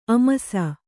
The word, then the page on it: ಆಮಿಸ: āmisa